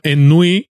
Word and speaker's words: Ennui: Entonces la película transcurre en una ciudad francesa inventada que se llama Ennui, la pronuncian así además, ennui sur pero está rodada en Angoulême, que es una ciudad francesa famosa por su salón del cómic.